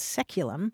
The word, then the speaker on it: saeculum: Anthony says the word that this caller is looking for is the Latin word saeculum, S-A-E-C-U-L-U-M, which in English is pronounced seculum.